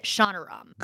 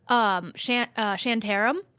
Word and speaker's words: Shantaram: but he pronounces it Shonaram. – Got it. – Shonaram. I've been saying it totally wrong. – What have you been saying? – Shantaram, I think is what I've been saying.